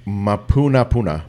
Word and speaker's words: Mapunapuna: So, Teddy's Bigger Burgers was closed down in Mapunapuna. – Mapunapuna. It's pronounced just like it looks.